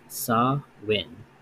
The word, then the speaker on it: Samhain: During Halloween, our annual time of Spider-Man costumes, candy binges, and slasher films, hundreds of thousands of Americans are observing the high holiday of Samhain, pronounced Sa-win.